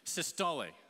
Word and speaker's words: systole: I know we have some doctors and scientists in the room because I was already corrected after the first service for pronouncing this the incorrect way, but it's systole.